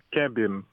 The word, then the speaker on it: Kemben: Est-ce que j'ai bien prononcé? Kemben, c'est bien? – Kemben. – Kemben, oui.